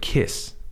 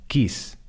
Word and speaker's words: kiss: The word kiss, beso, listen to it. Kiss, is, is. It's not geese, it's is. It rhymes with this. Kiss, this I'm not saying kiss, I'm saying kiss. Kiss is a short vowel sound. A lot of Spanish speakers would pronounce that quis. That would be a long vowel sound.